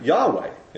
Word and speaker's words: Yahweh: Yahweh in the Hebrew. That's how we think we pronounce it.